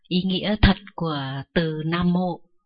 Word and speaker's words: Nam Mô: Nam Mô đó là một thuật ngữ được phiên âm từ tiếng Pali và Sanskrit, Nam Mô. Vì người Trung Quốc có một số từ họ không phát âm chuẩn như là người Việt Nam, cho nên họ viết chữ Nam Vô. Mà chữ Nam á, cuối cùng nó là M.